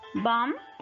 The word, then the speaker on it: bomb: Silent B, la B silenciosa. Bomb, clean y come. No decimos bomb o clean, no, sino que solamente vamos a pronunciar hasta la última letra antes de la B.